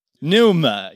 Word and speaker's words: pneuma: Pneuma.